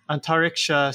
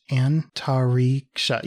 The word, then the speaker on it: Antariksha: And then the Antariksha. Yeah. Like, I feel like you said it right the first time.